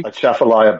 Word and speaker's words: Atchafalaya: Atchafalaya Basin Bridge.